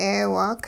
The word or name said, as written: 'eewaak